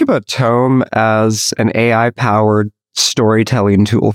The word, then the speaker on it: Tome: He is the CEO and co-founder of Tome, T-O-M-E.